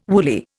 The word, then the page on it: woolly: Indian